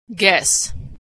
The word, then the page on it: guess: g; American English